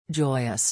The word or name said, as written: joyous